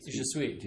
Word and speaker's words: je suis: In the same way that S-U-I-S becomes sweet, je suis.